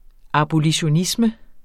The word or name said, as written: abolitionisme